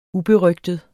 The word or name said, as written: uberygtet